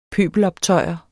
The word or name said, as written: pøbeloptøjer